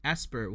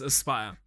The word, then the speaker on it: Aspyr: So it's spelled Aspyr, and in my head I was going, Asper, what's Aspyr? That makes so much more sense. – Yes, Aspyr.